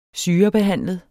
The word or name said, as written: syrebehandlet